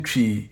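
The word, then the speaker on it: Twi: The beginning of tree is not pronounced exactly like the beginning of English words twin or twist. When you are pronouncing chi, you begin with a ch-like sound around your lips and finish with a long E sound. Chi.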